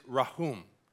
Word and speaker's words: rahum: Now, the verb part of compassion is rahum. Okay? Everybody say that. Rahum. Okay? The K is silent there as well.